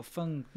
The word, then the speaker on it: Feng: No, F-E-N-G. Feng. – Yeah, well, Feng. Yeah, exactly. – We have different accents in Chinese. – Yeah, true. – Don't you dare say I'm wrong. Yeah, I said it correctly. – Sounded like you're saying like a, you know, like people always say like Feng Shui. – Yeah, Feng. It's Feng.